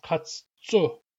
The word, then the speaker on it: katsu: The number three, katsa, gets pronounced katsu before a counting suffix that starts with a P.